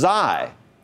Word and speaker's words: Xi: And we skipped the next one, which is pronounced Xi, like xylophone.